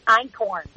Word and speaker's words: einkorn: So ancient grains would be really, honestly, einkorn. And that's spelled E-I-N-K-O-R-N. – Right. – And it's not a corn. People are always like, it's corn? No. It's actually a German word that, you know, essentially means, you know, one grain and one the great-grandparent of all varieties of wheat.